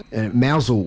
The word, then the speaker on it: Mousehole: Through some of these towns, Mousehole was another one I should mention. It's spelt Mouse Hole, it's Mousehole and Penzance.